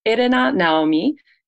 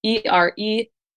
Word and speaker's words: Erena Naomi: But it's Erena Naomi, Erena Naomi, and it's my name pronounced phonetically in Japanese, the way I was called as a kid in Japan.